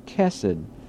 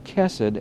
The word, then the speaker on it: chesed: This is the word, the Hebrew word, and it's pronounced chesed. The first letter is pronounced as ch, and then the middle letter is pronounced as an s, and then the word on the letter on the end is a d.